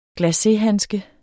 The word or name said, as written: glacéhandske